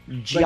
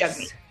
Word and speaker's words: Jagmeet: Well, it's not pronounced like jug. – Like jug? Okay. – And she says jug. – Okay. – Like a hillbilly jug. And that's not the way to pronounce it. But it's also not jag. – Mm. No. It's hard for, honestly, for white people to say, but it's yes. Jug-y. Yes.